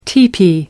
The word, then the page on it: tipi: British English